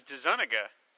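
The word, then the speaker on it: DeZuniga: It's 17 pages, written by Michael Fleischer, art by Tony Dezenuga. Why can't I say that? Tony Dezenuga? Please tell me how to say his name. – I always heard it was Dezenuga.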